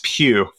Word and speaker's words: Pugh: It's Pugh, Florence Pugh.